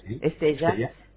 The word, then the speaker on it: Estella: Escuchaba hace unos días una conversación en el encuentro Estella, no sé cómo se pronuncia, Estella.